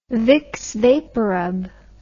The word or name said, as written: Vicks VapoRub